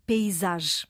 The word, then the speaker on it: paysage: É interessante, Filomena, porque esta palavra provém do francês e em francês o som é fechado. Paysage. Uhum. Eu vou soletrar em francês P-A-Y-S-A-G de gato, é paysage. Esta é a pronúncia francesa e provavelmente,